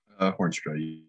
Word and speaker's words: Hornstra: Hornstra, yeah.